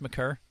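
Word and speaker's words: Maker: It's pronounced McCurr.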